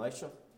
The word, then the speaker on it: Elisha: And Elisha, how do you say it? – Elisha. – Elisha? You guys prefer Elisha? Okay.